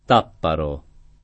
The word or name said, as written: Tapparo